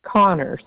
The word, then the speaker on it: Conner: My name is Jennifer, J-E-N-N-I-F-E-R. – And the last name is spelled? – Connor, C-O-N-N-E-R.